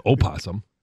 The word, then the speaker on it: opossum: No, nobody says opossum. – The silent O? – Those are the ones from Ireland.